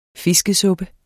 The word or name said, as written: fiskesuppe